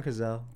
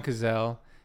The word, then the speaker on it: Cazale: John Cazale? Is that how we figured out how to say it? John Cazale. – John Cazale, yeah.